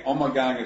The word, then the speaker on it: Omegang: Omegang is closer to the Flemish pronunciation, but a lot of people call it Omegang too. So really, it's kind of like tomato, tomato in a lot of ways.